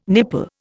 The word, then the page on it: nipple: Indian